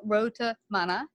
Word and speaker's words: Road to Mana: Well, I don't want us to leave without people finding out how they can get a wonderful copy of your book, Road to Mana. Did I say it right? – Yes, you said it perfectly.